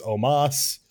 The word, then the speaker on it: Omos: I don't know why it's spelled Omos when they only pronounce it as Omas.